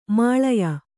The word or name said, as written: ಮೇಲಾಯ